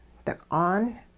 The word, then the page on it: diq'aan: hupa